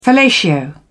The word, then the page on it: fellatio: British English